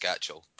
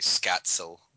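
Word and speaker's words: Skácel: Scatchell isn't pronounced Scatchell, but it's just stuck. It's actually Scatzel.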